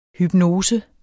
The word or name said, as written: HYPNOSERNES